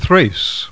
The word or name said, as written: Thrace